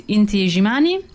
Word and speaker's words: Inti Illimani: And their name is Inti Illimani. – Inti Illimani per gli italiani. – That's right. I just discovered that this is how it's pronounced, the South American double L.